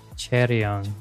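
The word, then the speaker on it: Chaeryeong: Four, I never knew how to pronounce this correctly. If you can pronounce this for me. Cheryeon. Yes, thank you.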